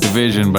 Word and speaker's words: DVSN: This one's a little R&B flavor from DVSN, but everybody pronounces it Division, but I think DVSN sounds cooler.